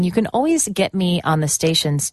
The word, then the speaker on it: Alvvays: That was Always with a beautiful song called In Undertown on XX1051. And here's the thing about the band. Their name is Always, but it's spelled A-L-V-V-A-Y-S.